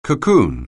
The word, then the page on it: cocoon: anglais